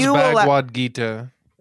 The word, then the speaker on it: Bhagavad Gita: It's pronounced Bhagavad Gita.